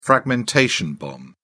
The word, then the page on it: fragmentation bomb: British English